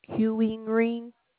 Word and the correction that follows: Keurig: I don't know how to pronounce it. Let me see how it looks. It's spelled K. The spelling of it is let me see, K E U R I G.